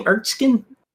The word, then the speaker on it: Erskine: Erskine.